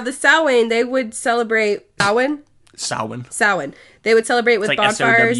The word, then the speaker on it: Samhain: It's like S-O-W-I-N. – Okay. – But it's not. That's not how it's spelled. – No. – It's S-A-M-H-A-I-N, but yeah. It's pronounced S-O-W-I-N. Samhain.